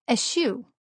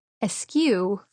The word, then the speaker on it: eschew: I realized that I had trouble pronouncing eschew. I've said askew for years. And so it's humbling to realize that you have said a word wrong your entire life.